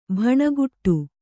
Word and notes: ಭಣಗುಡು: Kannada

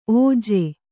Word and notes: ಓಝ: Kannada

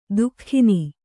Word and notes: ದುಃಖಿನಿ: duhkhini

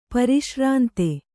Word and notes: ಪರಿಶ್ರಾಂತ: pari śrānta

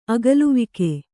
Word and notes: ಅಗಲೆವೋಗು: Kannada

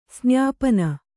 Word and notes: ಸ್ನಪನ: snapana

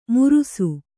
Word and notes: ಮುರುಸು: murusu

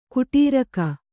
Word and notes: ಕುಟೀರಕ: kuṭīraka

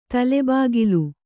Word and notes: ತಲೆಬಾಗಿಲು: kannada

